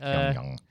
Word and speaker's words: Pyongyang: Pyongyang.